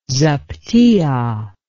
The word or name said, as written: zaptiah